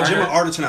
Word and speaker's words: Arterton: Yeah, Gemma Arterton, I believe.